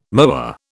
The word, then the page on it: moa: British English